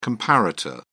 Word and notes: comparator: British English

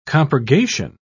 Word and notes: compurgation: British English

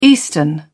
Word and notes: eastern: British English

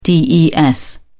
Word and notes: des: British English

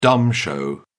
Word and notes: dumb show: British English

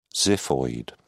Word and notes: xiphoid: British English

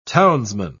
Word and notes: townsman: British English

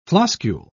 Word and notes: floscule: British English

